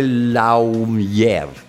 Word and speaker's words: lawyer: Avvocato è scritto L-A-W-Y-E-R. Raramente pronunciata bene. Colpa nostra pienamente, perché guarda come è scritto, no? Sarebbe lawyer, ma ignora come è scritta. It's lawyer. Se dovessi scrivere in italiano farei L-O-I e lawyer. È quel... quel che io chiamo un uomo morente, quel suono. Ecco, lawyer.